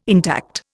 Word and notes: intact: Indian

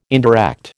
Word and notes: indirect: American English

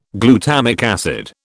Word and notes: glutamic acid: British English

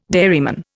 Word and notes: dairyman: Indian

